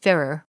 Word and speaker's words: Ferrar: Please use the Stuart Ferrer, last name is spelled Ferrar, but it's pronounced Ferrer, version for your memorization.